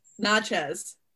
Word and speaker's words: Natchez: Natchez. – No, it's pronounced like not like nachos.